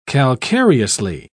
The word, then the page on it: calcareously: British English